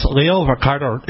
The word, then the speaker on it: Leo Varadkar: It's Leo Varadkar.